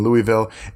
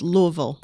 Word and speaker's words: Louisville: currently living in Louisville, and pursuing a PhD from the University of Kentucky. – I believe it's pronounced Louisville.